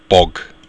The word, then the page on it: bog: Irish